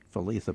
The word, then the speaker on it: Lethe: We should mention that Letha is L-E-T-H-E, by the way. – Right, and I was taught in school to pronounce it Letha, but he pronounces it Letha.